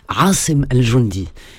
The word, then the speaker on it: Asim al-Jundi: Il s'appelle Asim, je vais le dire dans la vraie prononciation arabe. Asim al-Jundi. En français, ça se prononcera Asim al-Jundi, avec un A, deux S-E-M.